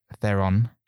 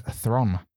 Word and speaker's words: Theron: On a side note, Charlize Theron, I heard that on a radio show that it's not pronounced Theron, it's Thron.